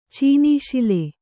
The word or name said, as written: ಚೀನೀಶಿಲೆ